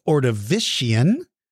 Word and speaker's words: Ordovician: The next is pronounced Ordovician, Silurian, Devonian, Mississippian, Pennsylvanian, and Permian.